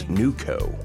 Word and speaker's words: Newco: George's Majestic Lounge in Fayetteville is going to have the band Newco on stage. – How do you spell that? – N-E-W-C-O. – Okay. – So think Wilco, except new instead of Will.